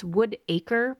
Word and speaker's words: Woodacre: Which, all of these towns that I'm mentioning here, Concord, Whittaker, it's not Whittaker, I keep saying it wrong. It's Woodacre, but it's one word, and when I say it fast, it sounds like Whittaker.